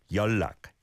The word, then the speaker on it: 연락: Yeah, and 연락 is one of those that really has the yonum, the sound blending. It's spelt 연락, but said 연락, 연락 for contact.